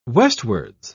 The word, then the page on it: westwards: British English